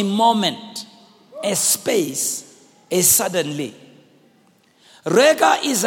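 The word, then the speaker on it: rega (רגע): We said that word little space is the Hebrew word R-E-G-A, pronounced rega.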